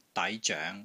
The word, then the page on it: DAI2 ZOENG2: Cantonese